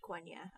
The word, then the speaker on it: Quenya: It's pronounced Quenya. I know it's not Kenya.